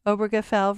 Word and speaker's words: Obergefell: Supreme Court in Obergefell versus Hodges. Am I pronouncing it correctly? – You sure are.